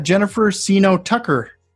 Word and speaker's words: Jennifer Seeno Tucker: We got Jennifer Seeno Tucker on the call.